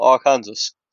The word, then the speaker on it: Arkansas: Right, you know the state that we would call Arkansas? Well, that looks like it's pronounced Arkansas. The Arkansas. – Oh, yes, that one. – Right. Apparently, it's prohibited to pronounce Arkansas incorrectly. Per the state code, the only acceptable pronunciation is in the first three syllables with the final S silent and the A in each syllable with an Italian sound. The accent on the first and last syllables.